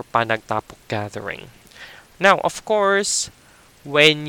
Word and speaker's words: panagtapok: we the one word for it is panagtapok.